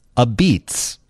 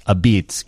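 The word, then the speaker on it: apizza: but it's pronounced differently. It's pronounced a beets, A-B-E-T-Z. It's a Neapolitan-style pizza. The best pizza pies come with claims on them, and trust us, this is among the best pizzas in America. It is pronounced a beets, even though it is spelled a pizza.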